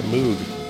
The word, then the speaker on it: Moog: Is it Moog? Moog. There's no umlauts involved.